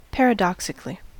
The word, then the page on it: paradoxically: American English